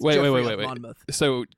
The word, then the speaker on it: Monmouth: It's Geoffrey of Monmouth. – Monmouth.